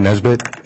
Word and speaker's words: Nesbitt: My name's Colin Nesbitt, and it's spelled N-E-S-B-I-T-T.